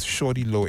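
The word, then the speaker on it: Shawty Lo: But to Prince, if you're doing the listening, the artist's name is Shorty Low. It's Shorty Low. It's spelled S-H-A-W-T-Y.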